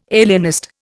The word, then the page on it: alienist: Indian